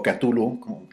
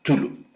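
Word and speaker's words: Cthulhu: o Catulu hay distintas maneras de pronunciarlo, Nunca en documental que dice que Lovecraft recomendaba como una tos, eh, Tulu. pero los americanos, sobre todo los norteamericanos, dicen Catulu, los ingleses también.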